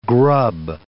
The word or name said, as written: grub